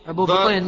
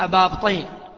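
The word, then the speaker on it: أبا بطين: يعني بيسكن الباء، نعم، بطين، وهكذا ننطقه، والعام ما ينطقونه أبابطين، البابطين، هو هو هو بطين.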